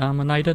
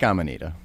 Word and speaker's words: Amanita: So should we be pronouncing it Amanita then? – We like Amanita. – Okay. – Just I think we settled sure that one.